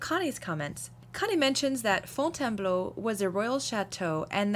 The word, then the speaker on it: Fontainebleau: Fontainebleau. – Bleu. – Bleu. – So phonetically, Fontaine, B-L-O, B-L-O-W, Bleu. – B-L-O-P-N-O.